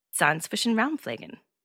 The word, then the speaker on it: Zahnzwischenraumpflegen: The correct pronunciation is Zahnzwischenraumpflegen.